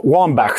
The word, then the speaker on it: Wambach: Abby Wambach. Om man nu säger så. Jag kan, det här uttalet. – Alltså, kan vi bara liksom ta det först? – För jag, jag har också alltid sagt Wambach. Ja. Wambach säger jag nog. Wambach. – Wambach.